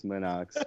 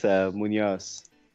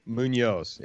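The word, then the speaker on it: Muñoz: If you want to say it wrong, yeah, it's Munox, but it's Munoz. – Munoz, it's the N with the tilde over there.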